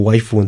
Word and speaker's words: waifu: Perdón, el término viene de del inglés wife, que es esposa, pero la pronunciación de los japoneses es como waifu.